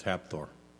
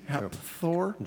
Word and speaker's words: Hafþór: It's Hapthor. – Hapthor? – Mm-hmm. – Is it pronounced with a P? Hapthor? – It's Hapthor.